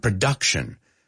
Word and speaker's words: production: Culture, cultivation, he oído culture, culture, cultivation, otro, production, no es production, production, palabras que reconocemos, pero las he oído pronunciadas mal miles de veces, te lo juro, I swear to God, so say them with me, no, el momento que, cultivation, cultivation, production, it's that schwa sound, production.